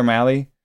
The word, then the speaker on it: Mahle: Those two and maybe Tyler Mahle, which you have to actually emphasize the whiteness when you say. – Mm-hmm. Mahle, like rally, it says on the pronunciation guide.